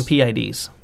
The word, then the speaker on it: PIDs: It then works on PIDs. Did I say that right? – Yeah, it's a PID loop and all that.